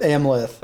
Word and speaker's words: Amleth: You know, Arlith? Is that how you say it? – Amleth. I think it's, like, it's Amleth. Just Hamlet, but they switched the letters.